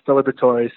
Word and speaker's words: Liberatore: Usually they forget the E at the end, so they actually don't do too bad. It's usually just Liberatore. Sometimes they butcher the B-R-E, the middle part, but for all of those who want to know, it's Liberatore, so it's not too complicated once you get it down and you say it the first time.